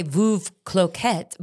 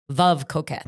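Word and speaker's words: Veuve Clicquot: Well, okay, in America, we say Veuve Cloquet, but it's actually pronounced Veuve, Veuve Cloquet.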